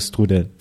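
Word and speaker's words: strudel: Nous avons dégusté des toasts avec de la pâte à tartiner à l'œuf et au thon, des strudels, je ne sais pas si je le prononce bien, comment on dit ? – Oui, c'est des strudels.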